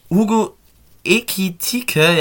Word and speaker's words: Hugo Ekitike: Hugo Ekitike, ja, jetzt habe ich es richtig ausgesprochen.